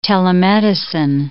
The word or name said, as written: telemedicine